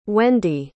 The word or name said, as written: wende